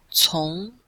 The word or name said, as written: cong2